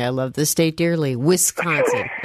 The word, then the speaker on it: Wisconsin: It's Wisconsin, not Wisconsin.